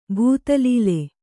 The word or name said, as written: ಭೂತಲೀಲೆ